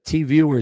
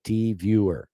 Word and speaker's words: Tvuer: Elizabeth, TVer is spelled T-V-U-E-R. TVer. It's my name, registered trademark, T-V-U-E-R. It's my own name, TVer. TVer. T-V-U-E-R. Download the app, T-V-U-E-R, in all the app stores.